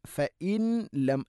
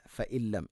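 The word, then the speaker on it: فإلم: വ അത്ഹറു ഫ ഇല്ലം തജിദു, ഫ ഇല്ലം, ഫ ഇൻ, ഫ ഇൻ ലം അല്ല, ഫ ഇല്ലം,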